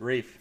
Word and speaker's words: Ralph: His name is Ralph Fiennes, right? – And he pronounces it Rafe for some reason.